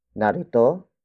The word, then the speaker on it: narito: Narito kina Raja ang mga sapatos ninyo.